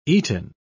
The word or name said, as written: eaten